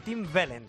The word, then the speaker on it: Tim Wellens: Tim Wellens, lo he dicho bien, ¿no? – Perfectamente bien, Tim Wellens.